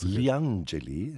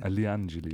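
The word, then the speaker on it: Li Angeli: Wie spricht man das aus? Glee? – Na, Lee. – Spricht man einfach nur Lee aus? – Liangeli. – Liangeli. Okay. – Liangeli, die Engel.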